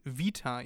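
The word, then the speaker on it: Vita (Βήτα): Äh, Alpha und dann kommt ja im altgriechischen Beta und das ist Vita jetzt, damit die Griechen Vita.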